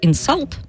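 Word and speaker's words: insult: Ну, читается как «инсалт», да, ну и «инсульт» нам привычное.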